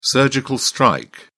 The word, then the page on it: surgical strike: British English